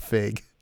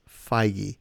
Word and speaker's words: Feige: One of my favorite stories ever, which I have bugged at Kev Feige. endlessly about over the years. His name is not Fig. His name is Feige. Kev Feige.